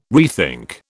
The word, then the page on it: rethink: British English